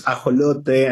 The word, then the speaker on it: ajolote: In Spanish, it's, you know, I think it sounds nice, right? It's ajolote, and it's a, and it's an endemic amphibious animal from Mexico.